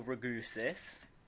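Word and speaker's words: Ragussis: It's a film directed by Daniel Raugwis, Ragusis. Written and directed. – Didn't butcher that at all. – Written and directed by Daniel Ragusis, who doesn't have a Wikipedia page.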